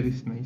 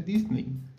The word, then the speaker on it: Disney: no se dice Disney, se dice Disney.